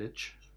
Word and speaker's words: Lich: It's kind of like upgraded Lich. Or Lick. I don't know how it's pronounced. – I think it's Lich. – Okay. – Definitely Lich.